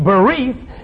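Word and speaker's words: berith: It's spelled B-R-Y-I-T-H, but it's pronounced B-E-R-E-E-T-H, bereath.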